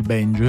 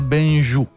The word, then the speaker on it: benju: No, non è il banjo, è il benju.